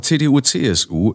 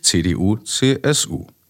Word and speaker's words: CDU-CSU: Die Aussprache CDU-CSU ist semantisch unsinnig. Christlich-Demokratische Union, Christlich-Soziale Union. Hä? Ah, gelesen wird der Fehler deutlich. Also richtig wäre christlich-soziale Union. Der Unterschied wird betont, zumindest im Deutschen. Folglich CDU-CSU.